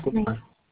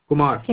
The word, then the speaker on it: Kumar: Karan Kumar. Kumar. – Can Kumar. – You spell it? – K-U-M-A-R.